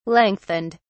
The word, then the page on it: lengthened: British English